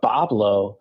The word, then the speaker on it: Boblo: And the pronunciation of Boblo comes from the French white wood, bois blanc, because, you know, in that part of Canada, they were speaking French. And so it was actually just a mispronunciation of the French, how we went from bois blanc to Boblo.